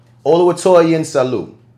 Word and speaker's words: Oluwatoyin Salu: Oluwatoyin Salu.